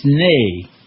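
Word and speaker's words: nee: But in Dutch, it's nay is how you say no.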